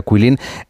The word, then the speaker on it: Guilin: Bueno, esta ciudad de Kuilin, por si alguien la busca en internet, se escribe G-U-I-L-I-N, como si fuera Guilin, pero se pronuncia Kuilin.